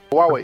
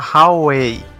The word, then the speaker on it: Huawei: E qual que é a pronúncia correta da marca? – Huawei? – Huawei. – Porque eu escutei muita gente falando Huawei, tipo, ignora o U. – Ah, pode ser também.